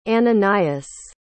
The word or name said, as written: ananias